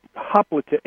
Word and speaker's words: hoplite: Hoplite, H-O-P-L-I-T-E, hoplite.